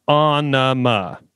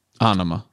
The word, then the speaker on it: Ænima: And then the clarification of how it's pronounced, Ænima. – Ænima. – And he said, and the song is Ænima. You see, the song is spelled E-N-E-M-A.